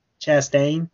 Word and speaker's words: Chastain: Is that Chastain? How you say it? – Chastain, yep.